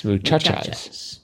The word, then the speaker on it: muchachas: Always the second to last syllable is accented. – Muchachas. Muchachas. – Muchachas, yes.